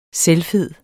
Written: selvfed

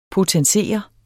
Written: potensere

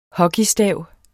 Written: hockeystav

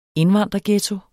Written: indvandrerghetto

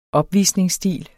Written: opvisningsstil

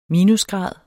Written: minusgrad

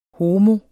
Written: homo

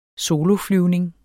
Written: soloflyvning

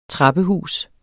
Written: trappehus

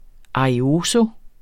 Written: arioso

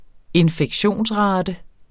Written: infektionsrate

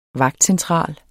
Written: vagtcentral

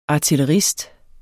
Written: artillerist

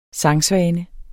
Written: sangsvane

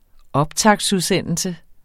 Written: optaktsudsendelse

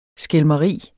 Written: skælmeri

